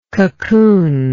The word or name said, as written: cocoonlike